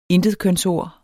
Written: intetkønsord

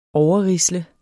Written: overrisle

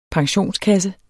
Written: pensionskasse

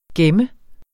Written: GEMMERNES

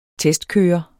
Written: testkører

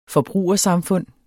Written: forbrugersamfund

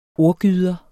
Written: ordgyder

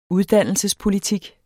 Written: uddannelsespolitik

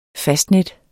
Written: fastnet

